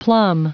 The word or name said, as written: plumb